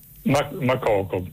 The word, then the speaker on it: Makoko: Mokoko con l'accento? – Makoko, sì, non so se è con l'accento finale alla francese, Makoko, però probabilmente siccome quello è un paese anglofono sarà in maniera diversa.